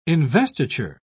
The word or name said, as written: investiture